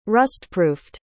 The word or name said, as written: rustproofed